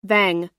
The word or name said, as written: vang